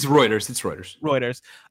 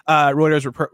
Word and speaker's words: Reuters: Router reports. Am I pronouncing that right? Is it Reuters? – It's Reuters. – Reuters reports.